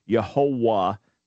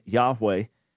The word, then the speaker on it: Yahweh: That's why people looking at the way things are written in manuscripts and not fully understanding the Hebrew scribal tradition centuries ago came up with Yehovah, which comes into English as Jehovah. But the best pronunciation, the most accurate pronunciation that we understand would have been Yahweh, which speaks of the one who brings all things into existence, or the one who causes things to be.